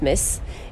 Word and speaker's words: MTZ: Nous sommes rue Blondel, où nous pouvons observer l'œuvre de Loïc Lugnat, Metz, MTZ, mais qui se prononce Metz.